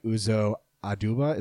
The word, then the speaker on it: Uzo Aduba: Shirley Chisholm, played by Uzo Aduba. Is that the right way to pronounce it? – Yeah.